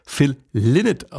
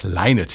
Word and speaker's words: Lynott: Die ARD-Aussprachedatenbank hat sich bei der Aussprache an den Kollegen der BBC orientiert. Und die Damen und Herren auf dieser Insel sagen laut BBC-Aussprachedatenbank Lynott hier zu hören.